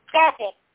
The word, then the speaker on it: Kevin: Kevin. K-E-V-I-N.